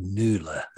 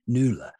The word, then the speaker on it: Nuula: We are so pleased to talk to you about an engagement letter product today that's relatively new to the market called Nuula. That's spelled K-N-U-U-L-A. Nuula.